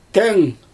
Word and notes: teŋ: Xyzyl

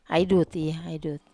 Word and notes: ai duti: Wamesa

